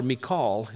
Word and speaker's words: Michal: Verse 20, so now Saul's daughter, Michal, is how it's pronounced in the original, was in love with David.